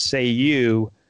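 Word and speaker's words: Cei-U: And he could summon him uh, by saying the mystical words uh, "say you." Right. But it's spelled C E I space U, but it's pronounced "say you."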